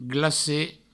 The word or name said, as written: glacet